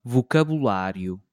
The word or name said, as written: vocabulário